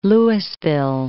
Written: Lewisville

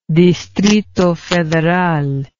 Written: distrito federal

